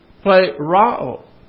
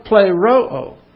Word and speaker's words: pleroo: P-L-E-R-O-O. I'm just going along with the crowd on this. Actually, it would be pronounced pleroo. Because you have an omicron and then an omega here. See, this is an omicron, pronounced all. And the omega pronounced O. So this would be play Rao. But I don't ever hear any. Have you ever heard anybody pronounce it that way?